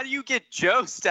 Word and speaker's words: Jost: I really want to talk about how you pronounce Amy Just's name, by the way. – Yeah, Jost, and then I correct myself and say Just.